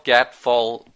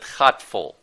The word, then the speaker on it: Gatvol: Oh, yes, yes, it's it's spelled Gatvol, but we pronounce it Hatfol.